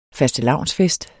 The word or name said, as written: FASTELAVNSFEST